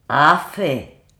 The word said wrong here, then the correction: Affe: Ah, ah, ah, non, en fait, ça se prononce pas comme ça, ça se prononce der Affe, die Affen.